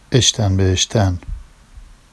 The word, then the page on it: eʃtan be eʃtan: Vafsi